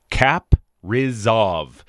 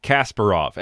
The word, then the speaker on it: Kaprizov: Kaprizov. Those last few letters are not the way I expected them to be. I know the name Kasparov came to mind. It's not. It's not Kasparov. And then it's like K-I-R-I-L-L.